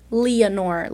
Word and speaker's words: Leonor: and, like, when I introduce myself as Leonor, I kind of say it like, oh, hi, my name is Leonor. Like, I don't say, like, Leonor, because I feel like people are like, wait, what?